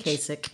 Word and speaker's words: Kasich: How do you say his name? Kasich? – Kasich. – Exactly. Kasich.